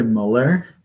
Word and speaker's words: Mueller: That's how everybody says it, but we say Mueller. – Oh, Mueller. My apologies. – It looks like Mueller.